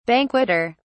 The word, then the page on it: banqueter: British English